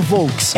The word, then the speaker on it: Volks: Como em alemão, a pronúncia correta do termo Volks era Volks, essa forma logo passou a ser utilizada no Brasil, evoluindo naturalmente para Fusca.